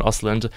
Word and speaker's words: Östlund: Struggle with the pronunciation, but it's Ruben Östlund, and it's a film that won the Palme d'Or at Cannes, which is like the top honor film festival award.